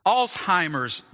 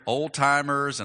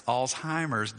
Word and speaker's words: Alzheimer's: Alzheimer's disease, that's how you say it. That's how it's spelled right there. I hear old-timers and all-timers. It's Alzheimer's disease.